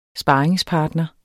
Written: sparringspartner

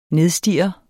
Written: nedstirre

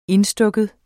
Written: indstukken

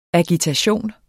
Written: agitation

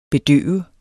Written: bedøve

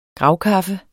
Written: gravkaffe

